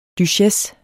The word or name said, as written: duchesse